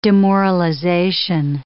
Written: demoralization

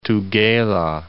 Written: Tugela

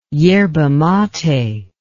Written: yerba mate

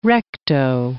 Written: recto

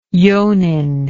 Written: johnin

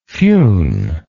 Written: fionn

